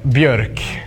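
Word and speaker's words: Björk: It's Bjork. Do like this. Bjork. Bjork. It's hard when you don't have those letters.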